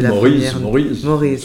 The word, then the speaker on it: Maurice: Oui, Maurice, simplement. Mm-hmm. Comme Maureen, mais avec un Z.